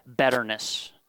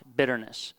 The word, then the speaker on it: betterness: betterness. And I'm not mispronouncing, and you're not hearing a Canadian accent. I am saying the word betterness, not bitterness.